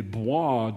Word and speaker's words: Bois: and you are taking a walk in the Bois de Vincennes, which you alone know how to pronounce of anybody in your family. This word that looks like boys is pronounced bois, and you know this.